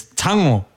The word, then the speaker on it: Tago: Everyone else is saying Tago, Tago this, Tago that. Tago, no. It is Tango. – Tango. – Yeah. – Tango. – Yeah, that's how you say it. Tango. – Makes a lot more sense, actually, yeah. – Yeah. T-A-G-O. A Samoan name, Tango.